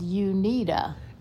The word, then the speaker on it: Uneeda: So listeners, it's spelled U-N-E-E-D-A, as in you need a...